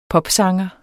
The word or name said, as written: popsanger